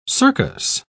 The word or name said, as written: circus